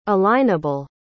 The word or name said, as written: alignable